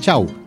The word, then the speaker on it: Shaw: Pero él era peruano y su apellido es inglés. Chau, es Chau. S-H-A-W. Raúl Chau Moreno. La W o la W se pronuncia como U. Chau.